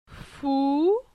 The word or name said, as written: Fou